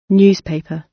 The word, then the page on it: newspaper: British English